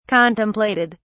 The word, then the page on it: contemplated: British English